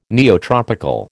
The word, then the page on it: neotropical: American English